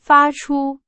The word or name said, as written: 发出